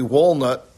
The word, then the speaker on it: Woolnoth: and then he went to London at St. Mary Walnut That walnut is spelled W-O-O-L-N-O-T-H.